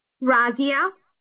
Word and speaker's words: Razia: Razia. – I can't. Your phone breaking up. I can't hear you. – Razia. – How you spell that? – R-A-Z-I-A.